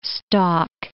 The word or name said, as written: stalk